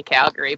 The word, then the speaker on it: Calgary: It's definitely Calgary, but I really enjoy you saying Calgary.